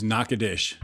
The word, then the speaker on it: Natchitoches: So Natchitoches. – Excellent. – Spelled Natchitoches, but don't say it that way. Why? – Oh, because people will get mad at you in Natchitoches. – It is Natchitoches.